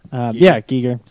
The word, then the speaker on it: Giger: Giger died at 74. – Oh, that's right. – Yeah. Giger. Sorry. Giger. Sorry, I'm English. – Sorry. – Or I'm American, so I pronounce things wrong.